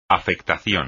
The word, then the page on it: afectación: espagnol